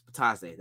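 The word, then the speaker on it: Bitadze: Batazde. Yes, Batazde. That's how you say it. Ah, I can't believe I was saying it wrong the whole time.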